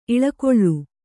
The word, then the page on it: ಇಲಿಗುಲಿ: kannada